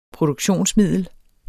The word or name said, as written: PRODUKTIONSMIDDEL